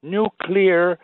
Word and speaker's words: nuclear: Now, the word nuclear has been mispronounced, you know, by presidents, including Eisenhower, Carter, George W. Bush, who they say used to do it deliberately, you know, for popularity reasons. Now, in your opinion, which is mine, actually, I believe that due to the fact that they did not see the word, you know, the spellings, mm-hmm. They thought nuclear was spelled NUCULAR rather than NUCLEAR.